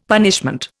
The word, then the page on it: punishment: Indian